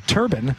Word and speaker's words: turbine: Turbine is actually the first pronunciation listed in the dictionary for turbines, and many in the industry, according to I thought so. – Mick Giggleberry. – You didn't think so. – Mick Giggleberry on Twitter, I think he knows what he's talking about, says many in the industry pronounce it as turbine.